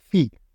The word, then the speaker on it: Phi: Phi como la letra griega, solo que los americanos lo pronuncian Phi, pero bueno, es Phi, escrito P-H-I, que es de Microsoft.